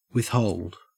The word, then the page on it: withhold: Australian English